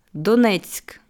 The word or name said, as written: Donezk